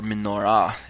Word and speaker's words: menorah: Um, and we also find the instructions for the golden lampstand, which we've come today to know as the menorah, um, or menorah, as it's pronounced